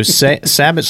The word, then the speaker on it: sabot: You want to call it, we could actually say, is it Sabot or Sabo? Sabot? – Well, that's whatever. – Just my interpretation. – That's how you say it. So, Sabot slug versus rifle slug.